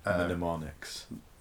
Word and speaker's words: mnemonics: They're called mnemonics. – That's it, yeah. Mnemonics. – Mnemonics? – No, you're saying it right, but okay. It's, mnemonics.